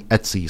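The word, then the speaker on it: Ötzi: And if there's a, on the Wikipedia article, there's a link to listen to the pronunciation and the person's clearly saying Etsy, so I'm going with that.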